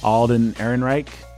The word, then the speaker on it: Ehrenreich: Alden Ehrenreich.